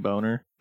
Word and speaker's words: Boehner: I think it was John Boehner, but his name is spelled like boner. Not like specifically, like B-O-H. And then it was like E-N-E-R or some shit. But we always called him Boner.